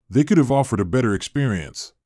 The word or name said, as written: could have offered